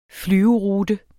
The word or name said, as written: flyverute